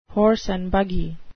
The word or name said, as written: horse-and-buggy